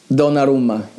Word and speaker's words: Donnarumma: Donnarumma, yes, he's brilliant.